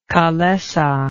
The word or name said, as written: calesa